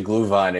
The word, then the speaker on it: Glühwein: Actually, you said Glühwein exactly, like, correctly.